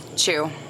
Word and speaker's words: Chiu: Chu. – How you spell it? – C-H-I-U.